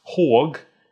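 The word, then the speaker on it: HÅG: And that is, it's pronounced Haug in Swedish, but that's H-A-G. So it's Western people generally say Haug, unless you're Australian, in which case you say Hag.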